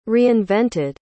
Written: reinvented

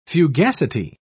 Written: fugacity

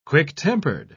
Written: quick-tempered

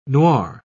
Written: noir

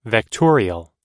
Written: vectorial

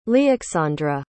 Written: lyaksandra

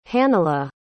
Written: hannela

